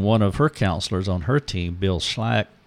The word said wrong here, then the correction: Schlacks: Did I say that right? – Mm-hmm. – Schlack. – Schlacks. – It's Schlacks, and it's S-C-H-L-A-C-K-S.